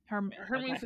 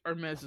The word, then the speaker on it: Hermes: Hermes? – Hermes is the Greek. Hermes is the brand.